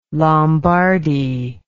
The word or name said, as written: lombardi